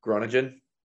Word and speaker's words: Groningen: Forgive me for pronouncing this one, to Groningen. – Groningen.